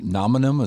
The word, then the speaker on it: Nominum: Well, to that, I mean, you guys did an uh, acquisition of, uh, is it Nominum? Is that how you pronounce the company that you bought? – Yes.